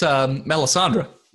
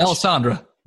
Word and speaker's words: Melisandre: Oh, that's Melisandre.